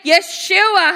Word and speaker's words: Yeshua: and that is the name Yeshua, hallelujah. I know it says Jesus in English, but Yeshua is the original pronunciation of that name.